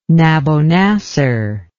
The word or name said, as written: Nabonassar